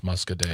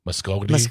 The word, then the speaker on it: Muscadet: I'm so glad I work here because I would have never known how to pronounce Muscadet because I pronounced it, I think, as like Muscoda.